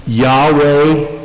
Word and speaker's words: Yahweh: Yahweh is the way it's generally pronounced by scholars.